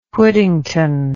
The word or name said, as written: Whittington